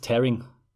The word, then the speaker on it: tearing: Äh, Tears sind die Tränen und to tear heißt zerreißen und genau das passiert ja mit dem Bild, das ist Tearing.